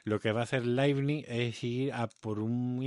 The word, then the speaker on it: Leibniz: Bueno, pues sí, eh, pasando de, de Descartes nos encontramos con Leibniz, que es otro filósofo racionalista, este, este, es alemán, así que se pronuncia Leibniz, del siglo...